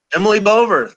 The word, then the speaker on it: Bover: Emily Bover, am I saying that right? – Emily Bover, that's right.